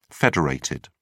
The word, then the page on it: federated: British English